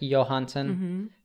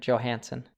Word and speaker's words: Johansson: It's one that even natives pronounce wrongly because basically people will think it should be pronounced like in Scandinavia. So it would be like Johansson, but we pronounce the J, you know, like an American Johansson.